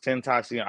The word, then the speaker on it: Tentacion: Tentacion. – I never knew how to say that. – I believe that's how it's pronounced, Tentacion.